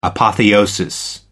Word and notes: apotheosis: American English

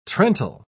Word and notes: trental: British English

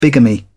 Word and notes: bigamy: British English

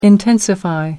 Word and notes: intensify: American English

